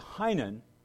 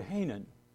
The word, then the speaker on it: Heynen: There are two pronunciations of my name. My dad said Heynen. My mother invariably revised it to Heynen. So when I'm introduced as Heynen, I think, you know, that's just fine. Even though my dad said Heynen, my mom said Heynen.